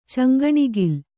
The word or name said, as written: ಚಂಗಣಿಗಿಲು